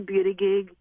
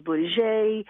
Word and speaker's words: Buttigieg: So, it's pronounced here in a way that can be somewhat spelt. So we say Buttigieg. And there's, again, different variation. If you live on Park Avenue, New York, it's pronounced Buttigieg.